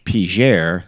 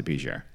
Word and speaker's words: Piger: It is spelled P-E-I-G-N-E-U-R. So in French, I don't know, Pignor or something like that. But the locals pronounce it Piger, so I'm going to pronounce it Piger.